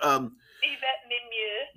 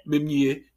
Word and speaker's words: Yvette Mimieux: But Yvette Mimieux. – Mimieux, okay. – Mimieux.